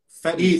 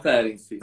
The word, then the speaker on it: Ferenczi: É Ferenzi que pronuncia? Ferenzi. Isso, é o Ferenzi.